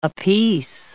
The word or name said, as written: apiece